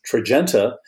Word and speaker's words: Tradjenta: It is about linagliptin, which is Tradjenta, which is spelled Tradjenta. I don't know why they had to stick that D in there, but Tradjenta and its effect on cardiovascular mortality.